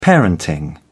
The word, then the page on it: parenting: British English